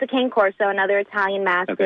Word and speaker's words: Cane Corso: Well, there's different pronunciations, but, yeah, Cane Corso is how we always pronounce it.